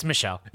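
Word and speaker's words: Michel: Yeah, and the French pronunciation is Mikael. No, Mikael. It's Michelle.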